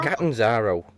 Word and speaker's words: Catanzaro: Canzaro. – Catanzaro. I never remember how to pronounce it.